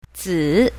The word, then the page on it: zi3: mandarin